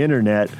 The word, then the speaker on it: internet: And if it's on the internet, if it's on the World Wide Webs, it must be true. – It's internet. Make sure you pronounce all the letters.